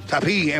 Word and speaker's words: Tapie: Depende de la pronunciación. Tapie, claro, claro. – Tapie en francés.